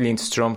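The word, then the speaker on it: Lindstrøm: توی این سوالی که پرسیدی میتونه بهترین باشه تو سوئدیش حالا اگه بود لینستروم تلفظ میشد یسپر لینستروم حالا نمیدونم توی دینیشش اون اسش شه تلفظ میشه را یا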